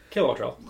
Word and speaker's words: Kilowattrel: Early in the run, I was going to do Kilowattrel with... Is that how to say it? I don't know. – Yeah, Kilowattrel.